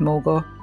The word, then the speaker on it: Morgawr: And um, the most famous of them, I would guess, is the Morgawr, although the name is pronounced in several different ways. But I'm going to stick with Morgawr,